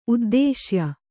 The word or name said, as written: ಉದ್ದಿಶ್ಯ